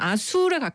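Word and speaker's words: Azul: 아줄. 정확히 그쪽 원어 발음은 아술에 가깝더라고요.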